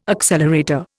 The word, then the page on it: accelerator: Indian